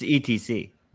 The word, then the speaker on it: ETC: Wow, you just pronounced it Etsy. That's interesting. That's incorrect. What? It's ETC, right? – Okay, sure.